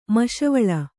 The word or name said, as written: ಮಸ್ಸೀವಾಲ